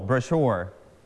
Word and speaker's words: brochure: นักเรียนต้นทางหมดแล้วโบชัวเรียนไปทางโบชัวเหมือนกันนะครับ in Thai in English we call repeat after me brochure brochure travel brochure คำนี้พิเศษมากนะครับคำที่เรารู้จักคำว่าโบชัวเนี่ยคนอเมริกันนะครับอังกฤษแบบอเมริกันจะอ่านว่า brochure เน้นที่พยางค์ที่สองสีชมพูที่ครูเน้นไว้ brochure แต่คนอังกฤษเขาจะเน้นที่พยางค์แรกนะครับต่างกันเขาจะอ่านว่า brochure brochure brochure and brochure